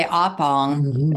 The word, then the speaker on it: Oppong: I'd say Oppong. – Okay. – Geez, O-P-P-O-N-G.